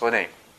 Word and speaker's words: René: But in French, it's all the same. It's Renee.